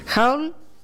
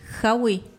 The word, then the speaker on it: haul: Haul. – Howie. – No, no. Se escribe H-A-U-L. – H-A-U-L.